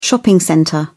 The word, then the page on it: shopping centre: British English